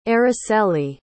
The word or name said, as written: Araceli